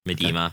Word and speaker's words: Miedema: Uh, but I know uh, Vivian Mirdama or however you pronounce her name, Miedema. Okay.